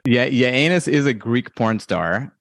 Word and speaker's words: Giannis: Giannis is correct.